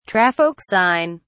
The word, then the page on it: traffic sign: British English